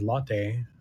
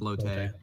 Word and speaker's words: Lotte: So the first, the actress that voice Lotte, or I'm not sure how they pronounce it in the English. Is just Lot. – They don't say Lot. It's like Lotte. – Lotte, right?